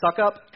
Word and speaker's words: Succop: I'm not mispronouncing. It's pronounced his name. His name is Ryan Succop.